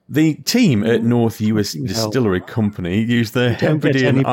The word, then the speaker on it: Uist: I think it's pronounced Uist, but... – Oh yeah, there's an I in that. Yep, it is Uist.